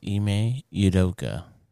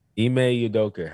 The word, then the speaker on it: Ime Udoka: I believe it's Ime Udoka. – Ime Udoka.